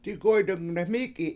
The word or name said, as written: digOy7dung deMIIk7i7